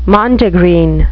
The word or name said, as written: mondegreen